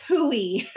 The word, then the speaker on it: Hui: It's Hooey.